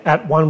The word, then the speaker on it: atone: We don't say at one, we say atone, at own.